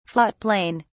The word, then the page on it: float plane: British English